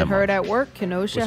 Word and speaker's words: Kenosha: Is that how you say it? I haven't listened to the news enough to know the proper way of saying it. – We call it Kenosha.